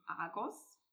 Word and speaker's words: Argos: Argus oder Argus-Augen ist benannt nach dem 100-äugigen Riesen Argos oder auch eben Argus ausgesprochen, der Allesseher.